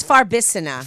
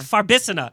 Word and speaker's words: Farbissina: Because it's it's Farbissina. – Farbissina.